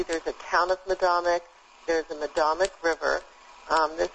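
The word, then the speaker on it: Medomak: Now, the camp is called Madomic, and am I pronouncing that correctly? – Yes, you are. – And I'm going to spell it for the listeners. It's M-E-D-O-M-A-K.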